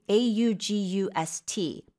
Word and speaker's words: august: A U G U S T. 8월이랑 같은 스펠링인데 yeah. – 의미가 다르죠. – Yeah, the stress is on the second syllable mm-hmm.